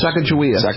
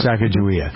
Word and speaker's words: Sacagawea: Sacagawea. – Thank you. Yep. Sacagawea.